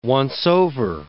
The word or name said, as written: once-over